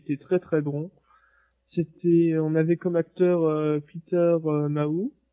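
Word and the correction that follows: Mayhew: Je me rappelle plus comment on prononce. Mayu, voilà, qui incarne donc Chewbacca.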